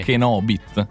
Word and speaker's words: Kenobit: Perché ti viene da mettere l'accento sul bit e invece l'accento sì, è sul sì. Kenobit.